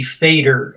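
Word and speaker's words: Feder: And now I'm not correcting your pronunciation because my German isn't very good, but it may be Fader.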